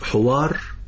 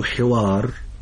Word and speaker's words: حوار: كلمة الحوار احذر أن تنطقها بضم الحاء. لماذا؟ لأن كلمة حوار بضم الحاء تعني ولد الناقة، والجمع أحورة وحيران. أما كلمة حوار بكسر الحاء فتعني ذلك النقاش، ذلك الجدال، ذلك الحديث المتبادل بين طرفين.